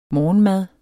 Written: morgenmad

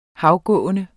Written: havgående

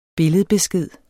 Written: BILLEDBESKED

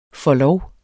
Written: forlov

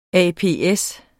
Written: ApS

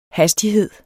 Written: hastighed

